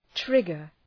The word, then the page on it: trigger: ‘trıgər